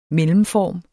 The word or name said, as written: mellemform